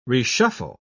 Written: reshuffle

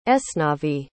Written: esnavi

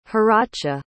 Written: haracha

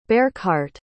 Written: beircheart

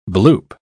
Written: bloop

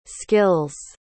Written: skills

